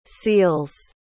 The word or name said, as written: seals